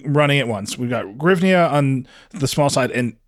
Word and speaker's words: hryvnia: In English, I think it's hryvnia. – Hryvnia is good, yeah. Hryvnia is very good, yeah.